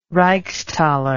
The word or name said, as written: reichsthaler